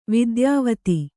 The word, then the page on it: ವಿದ್ಯಾವತಿ: Kannada